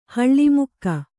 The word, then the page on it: ಹಳಮೂಕ: Kannada